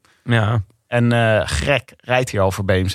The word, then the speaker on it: Greg: Dus twee hoofdrolspelers in deze koers zijn een hele jonge Greg van Avermaat. – Ja. – Winzaam, ik soms vergeet dat ze me uitspreken als Greg en niet Grek.